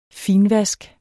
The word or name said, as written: finvask